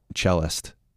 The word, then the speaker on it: cellist: And so you could be the best concert cellist in your, is that how you say it? – Yeah.